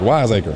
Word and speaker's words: Wiseacre: How you pronounce it? Weisskirch Brewing Company. – We gonna say Wiseacre. Is that what that is? – Yeah, or Wiseacre maybe. – Wiseacre. – One word. Wiseacre.